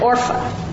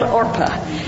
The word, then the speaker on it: Orpah: I always said Orpha, but it's spelled Orpah.